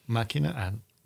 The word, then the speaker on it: Machina: Is it Machina? – Machina. – That sounds more familiar.